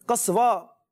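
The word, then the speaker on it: قصواء: اچھا جی, حضرت ایک میسج پہ ایک وہ آیا ہے, وہ ہے اپنے اسوہ نام, نام اسوہ تو نہیں, قصوہ یہ اصل تلفظ اس کا.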